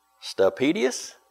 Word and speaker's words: Stapedius: I asked him last week, I said, okay, how do you pronounce that? Stapedius? Okay. Very good.